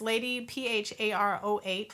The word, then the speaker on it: Lady PharOh: So my name is Lady PharOh. Look it up. That's how I spell it. There's no extra A's.